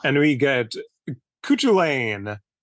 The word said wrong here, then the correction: Cuchulain: I believe it's actually Cuchulain. – Oh, that sounds better.